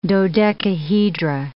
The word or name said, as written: dodecahedra